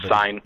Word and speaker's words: Cine: Is it C-I-N-E is how it's written? – Sign. I just say sign.